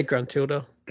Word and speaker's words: Gruntilda: From Gruntilda. Is that how you say it? Gruntilda? – Yeah, Gruntilda.